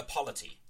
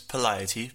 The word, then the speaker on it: polity: polity. So, like, comparing... – It's polity, but... Polity. Yeah, I suppose we've gotten used to your mispronunciation.